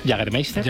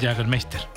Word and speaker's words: Jägermeister: Jägermeister. – Jägermeister.